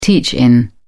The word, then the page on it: teach-in: British English